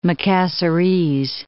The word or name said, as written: Makassarese